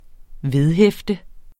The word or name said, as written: vedhæfte